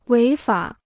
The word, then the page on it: 违法: wéi fǎ